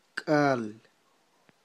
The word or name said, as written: k'al